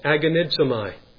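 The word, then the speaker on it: agonizomai (ἀγωνίζομαι): The two words there, laboring earnestly, they are really one word in the Greek language, and the word is pronounced agonizomai. We get our English word agony from it.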